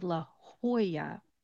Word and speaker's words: Jolla: You might think it's Joy La or something along those lines. But again, we're going to follow those Spanish speaking rural names. You're going to actually make that J into an H sound. The two L's are going to become a Y. So it becomes La Jolla, Jolla, when you pronounce it.